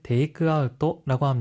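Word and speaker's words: テイクアウト: 아웃은 일본어로 아웃토라고 발음하기 때문에 테이크아웃은 테이크아웃토라고 합니다.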